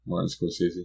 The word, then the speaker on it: Martin Scorsese: Martin Scorsese.